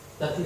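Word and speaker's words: Lapin: Ganz witzig betont man immer nur die Gipfel, was sie gemacht. Immer, immer die erste Silbe. Lappin. Ja, irgendwie so, so Gerade mal der dann kriegst du nämlich eins aus dem Bettwitz, das ist nämlich mein Lieblingsbier.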